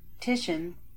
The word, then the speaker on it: Titian: Titian.